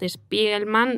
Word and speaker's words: Spiegelman: el cómic Mouse, eh, que es un cómic maravilloso, eh, ganador del premio Pulitzer, de Art Spiegelman, no sé si lo pronuncio bien.